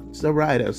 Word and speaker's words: Zoraida: Zoraida. – So yeah, yes, like Ryder, you know, Zoraida.